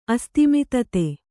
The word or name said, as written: ಅಸ್ತಿಮಿತತೆ